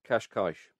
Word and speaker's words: Cascais: I was born in, I think it's Cassay or Cascais. – Can you spell it? – C-A-S-C-A-I-S. – Cascais. – Cascais. Yep. Is that Cascais? – So it's Portuguese then.